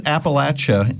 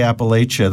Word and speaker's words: Appalachia: They seem to back up in the computer, but this is a book about they pronounce it Appalachia there, obviously. I say Appalachia. That's what I was sort of brought up saying. I think both are somewhat correct.